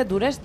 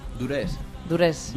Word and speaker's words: Duress: Precisamente son la voz y la guitarra de Ander Dures. Bueno, Ander, ¿se dice Dures? ¿Dares? ¿Cómo se dice esto? – Dures. – Dures. – Vamos a poner una tilde imaginaria para no decirlo mal.